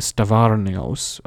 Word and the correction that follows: Stavrianos: I probably mispronounced his name horribly, Stavrianos, that's how you say it, Leftin Stavrianos, introduced the first real kind of course called world history.